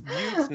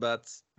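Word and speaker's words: Buttes: It's butts. – No, He pronounces it butts.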